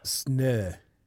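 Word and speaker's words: Snö: Sne. S N O with two pricks. Okay. Snow.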